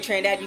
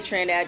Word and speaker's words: Trinidad: My name is TK Trinidad. You can follow me on all social media at TK Trinidad, just in case you don't want to spell it, T-R-I-N-I-D-A-D, on everything, Instagram, Twitter, all that good stuff.